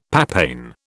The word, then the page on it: papain: British English